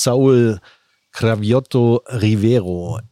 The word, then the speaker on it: Saúl Cravioto Rivero: Ich hoffe, ich spreche ihn richtig aus. Saúl Cravioto Rivero. Ist das richtig? So das ungefähr? Hat sich gut angehört. – Ja. Nein, das ist richtig.